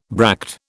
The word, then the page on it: bract: British English